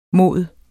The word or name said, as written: mod